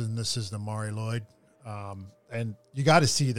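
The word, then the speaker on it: Lwyd: It's M-A-R-I. That's the first word. And then Lloyd, because it's Welsh, it's spelled oddly. It's L-W-Y-D.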